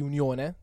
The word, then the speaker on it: UniOne: un significato di unione, dato che appunto letteralmente UniOne in italiano si legge unione.